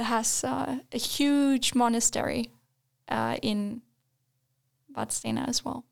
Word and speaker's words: Vadstena: And then Vadstena. Or as the locals say, Vadstena.